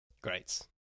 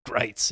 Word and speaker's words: Gritz: I guess some of the people that were there were just like grits. It rhymes with whites, and I was like, grits does not rhyme with whites.